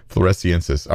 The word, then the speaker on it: floresiensis: Floresiantis? I don't even know how to pronounce that one. Floresiensis.